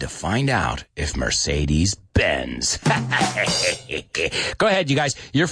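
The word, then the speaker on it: Benz: Now, if you say it quickly, bends and bends, sounds exactly the same. So what does that mean? That means that that D, cuando ponemos esa D, cuando ponemos la S después, no pronunciamos ese, esa D. Listen to it, right?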